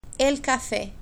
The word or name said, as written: el cafe